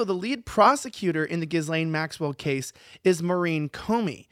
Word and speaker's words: Ghislaine: Okay, no, but I'm saying it's Ghislaine. Say it right.